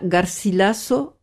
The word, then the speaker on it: Garcilaso: Inca Garcilaso, o Garcilaso, si on veut le prononcer à l'espagnol, de la Vega.